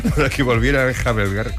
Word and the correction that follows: enjalbegar: ¿cómo se dice? Enjalbegar la pared.